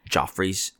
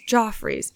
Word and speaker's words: Joffrey's: Okay, I might be saying this wrong, but Jeffrey's Donuts. It's like... – Joffrey's. It's their coffee. – That's what it is. Joffrey's.